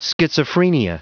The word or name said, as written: schizophrenia